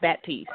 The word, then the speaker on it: Batiste: My last name is pronounced Batiste. Like, if you've seen the movie Eve's Bayou and they had the Batiste, that's exactly how it's pronounced.